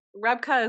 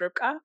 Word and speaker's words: Rebeka: So Rebeka is actually like the easier American pronunciation of my name, which traditionally it's pronounced Rebeka, but I think Rebeka, again? – Rebeka.